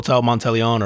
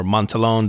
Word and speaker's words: Monteleone: It's in a hotel called Hotel Monteleone or Montelon, depending on how you pronounce it, because they have all the French fucking pronunciations, everything.